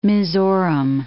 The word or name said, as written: Mizoram